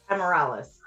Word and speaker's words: Ameralis: Ameralis.